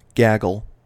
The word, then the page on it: gaggle: American English